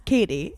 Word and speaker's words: Cady: And her name is Katy Heron. Katy Heron. – It's Katie. It's spelled Katie. C-A-D-Y, but yeah, it's Katie.